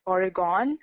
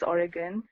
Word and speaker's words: Oregon: Well, I used to even pronounce it Oregon until someone on the plane corrected me and told me it's Oregon.